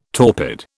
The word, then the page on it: torpid: British English